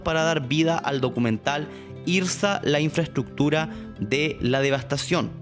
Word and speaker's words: IRSA: Pues bien, comencemos con una definición para entender qué es lo que es IRSA.